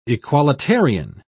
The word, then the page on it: equalitarian: British English